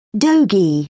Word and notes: dogie: British English

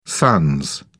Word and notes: sans: British English

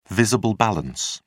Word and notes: visible balance: British English

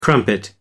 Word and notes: crumpet: American English

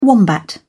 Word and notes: wombat: British English

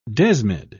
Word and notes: desmid: British English

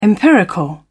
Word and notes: empirical: British English

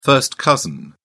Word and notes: first cousin: British English

